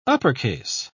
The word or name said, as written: uppercase